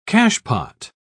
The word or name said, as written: cachepot